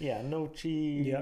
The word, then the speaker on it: gnocchi: We've got palushki, which is potato kind of gnocchi. – Gnocchi. That's how you pronounce it.